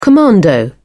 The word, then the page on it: commando: British English